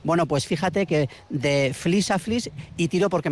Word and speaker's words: Flix: Es un pueblo de Tarragona, lo que pasa es que se escribe F-L-I-X. – Pero o sea, no es el Flis que estamos viendo aquí. – No, en catalán se pronuncia Flis.